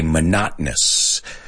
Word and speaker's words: monotonous: But the pronunciation is what worries me here, because in Spanish, you guys say monótono. We don't. We say monotonous. And there's that that stop in English. Monotonous.